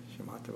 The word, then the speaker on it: Shamatha: Shamatha Vipassana. Shamatha, with the emphasis on the ma, rather than just kind of a flat Shamatha.